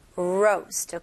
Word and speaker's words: roast: roast 같은 경우는 이 R 발음을 명확하게 해주셔야 됩니다. 혀가 천장을 닿으면 그 순간에 L 발음이 되거든요. 그래서 천장을 닿으면 안 돼요. roast, okay? 혀가 뒤로 당기는 느낌으로 꼬부리면서 roast, roast, okay? 그리고 이거는 짧은 모음이 아니고 살짝 긴 모음입니다. 그래서 roast가 아니고 roast. 제 입모양 보이시죠?